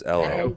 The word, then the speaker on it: Ello: E-L-L-O. – Hello. – Oh, like Hello. – Jello without the J. – No, it's more like hello without the H, you know, the British thing.